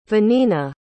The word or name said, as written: vanina